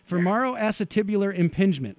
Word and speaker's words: femoral acetabular impingement: Femoral acetabular impingement, how did I do? – Awesome. That was great.